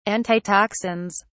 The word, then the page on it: antitoxins: British English